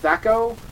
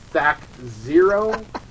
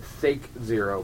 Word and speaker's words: THAC0: And is pronounced Thaco or Thaco or Thac 0. There was one crazy ass kid who was on some sort of ADD medication who pronounced it Thake Zero, but we never played with him.